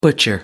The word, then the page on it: butcher: American English